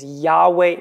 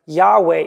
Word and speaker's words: Yahweh: And the interesting thing about God's name that's given here in Exodus chapter 3 is that we're actually not sure how it's pronounced. The reason for that is the word given here that we pronounce Yahweh is four letters, Y-H-W-H. But the Hebrew alphabet only has 12 letters and there's no vowels.